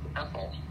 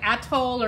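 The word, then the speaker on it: Athole: And she had an older sister, a foal, which it's taken me years to find out that a foal, H-E-H-O-L-E, is actually pronounced Ethel. – Yeah. I always thought it was like Atoll or something, or Atoll or something.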